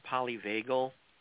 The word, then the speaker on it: polyvagal: Tell us about do you pronounce it polyvagal theory? – Yes.